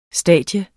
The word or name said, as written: stadium